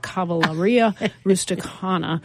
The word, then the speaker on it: Cavalleria Rusticana: featuring performances of Puccini's Suor Angelica and Mascagni's Cavalleria. Oh my goodness, I butchered that. Cavalleria Rusticana.